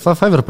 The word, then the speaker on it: Fiverr: Правильно, Fiverr, Fiverr произносится? Да, да.